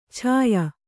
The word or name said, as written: ಛಾಯ